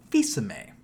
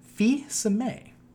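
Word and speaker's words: Fils-Aimé: My PSA of the day is, it's pronounced Fils-aime. Fils-aime. Weird, right? – It's pretty weird.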